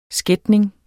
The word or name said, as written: skætning